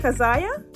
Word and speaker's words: Kazayah: Am I saying your name correctly? Is it Kazayah? – Yes, Kazayah. – Oh, yeah. See that? Got it right. And I am loving the name. But Kazayah, let me tell you.